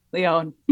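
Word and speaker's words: Lyon: yeah, I there's one episode in particular when I was talking about a place in France that I now understand is pronounced Leon. but I said lion the entire time and uh, people let us know.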